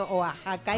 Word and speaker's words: Oaxaca: Que en México la X se pronuncia J. Entonces que cuando lea Oaxaca, sí, no lea Oaxaca, sino Oaxaca, y entonces me van a entender.